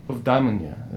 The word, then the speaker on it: Eudaimonia: As yeah, her album's called Eudaimonia, but a Greek person told me it was Eudaimonia, is like how you pronounce it in Greek, I guess.